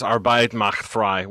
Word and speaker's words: Arbeit macht frei: I think it's pronounced Arbeit macht frei, which means work means freedom, like loosely.